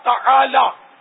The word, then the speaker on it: تعالی: تعالی عین حلق سے نکالنا ہوگا, تو یہ سب کا نکلتا نہیں ہے. تو عین نکلتا ہے, وہ بھی تالا بولتے ہیں.